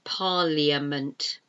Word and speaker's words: parliament: But it's B-U-S-I-N-E-S-S. You don't pronounce that middle I, business. And parliament, parliament. It should be pronounced parliament, but it's not. It's pronounced parliament. It's a silent I, P-A-R-L-I-A-M-E-N-T.